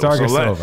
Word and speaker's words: Sargisova: Did I even say her name right? – No. – I hacked it, didn't I? Yeah. I don't care. Let's still roll with it. – Sargisova.